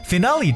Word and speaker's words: finale: 最后给大家去分享的一个单词叫 finale，这个单词要特别注意它的发音。 finale is spells f i n a l e，finale 重音在后。 finale 这个词大家会想到是 final，对不对？